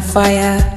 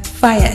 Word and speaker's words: Fya: My name is I Am Fire. Fire is spelt F-Y-A.